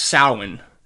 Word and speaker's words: Samhain: In it comes down to a Celtic festival pronounced Samhain,